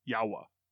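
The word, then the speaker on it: Yowa: It's Y-O-W-A. Yawa.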